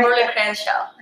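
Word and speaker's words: Kimberlé Crenshaw: Sí, mi pronunciación está correcta. Kimberly Crenshaw.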